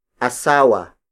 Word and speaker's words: asawa: Unlike Tagalog, which uses a gender-neutral word, asawa. Asawa. Asawa. Asawa is spelled as A-S-A-W-A.